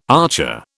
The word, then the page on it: archer: British English